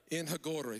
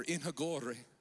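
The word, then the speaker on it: En Hakkore: In Hagore. Somebody afraid you're going to say it. Just roll your R. In Hagore.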